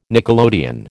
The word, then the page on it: nickelodeon: American English